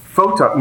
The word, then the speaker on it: photon: For the more Cape Canaveral, less SpaceX people out there, what does floton mean? – Oh, photon. You mean photon torpedo? – Photon. See, I even pronounced it wrong.